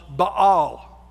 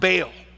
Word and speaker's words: Baal: Jezebel was the daughter of a Phoenician king, who was also the king was also the high priest of Baal, or sometimes pronounced Baal, all right? Either one is fine.